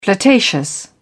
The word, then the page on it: flirtatious: British English